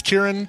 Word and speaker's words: Ciaran: Did I pronounce Kieran correctly, by the way, Claire? – Yeah. – C-I-A-R-A-N.